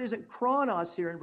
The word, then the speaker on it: chronos: That's how it be pronounced in the Greek, chronos.